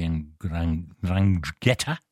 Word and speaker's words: 'Ndrangheta: My next guest is the author of a number of books on the 'Ndrangheta. That's how you say it. It's a very difficult word.